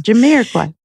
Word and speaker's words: Jamiroquai: Jamiroquai. – Jamiroquai.